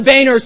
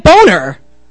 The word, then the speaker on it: Boehner: It is the Speaker of the House, and everyone mispronounces its name, calling it Boner, when it's actually pronounced Boehner. It's German.